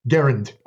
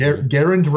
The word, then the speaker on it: Garand: Garand. – Oh, see, like I just learned something. – Garand rhymes with errand.